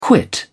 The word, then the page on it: quit: British English